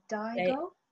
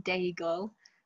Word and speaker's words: Daigle: So my favorite worship song is You Say by, I don't know how to say her last name, Lauren Daigle. – Lauren let me say that again. My favorite worship song is You Say by Lauren Daigle, and it's not necessarily a song that I've sung in a like gathered worship setting.